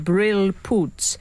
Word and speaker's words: brilpoets: In Holland, the word for a wet wipe for your spectacles is pronounced brilpoets, but it says Brill Poets on the packet, which I always read in the voice of Frank in his excellent poetry podcast.